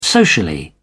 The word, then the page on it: socially: British English